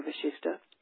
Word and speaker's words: Vasishtha: It's called the Concise Yoga Vasishtha, spelled V-A-S-I-S-T-H-A or V-A-S-I-S-H-T-H-A, because the second sha in Vasishtha is pronounced like S-H, so it's sometimes spelled with an S-H.